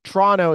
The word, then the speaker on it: Toronto: And by the way, it's Toronto, Toronto, Toronto is how they say it here. Uh, I kept saying Toronto. I think California people like myself, they say Toronto. It's it's neither. It's Toronto. So whatever.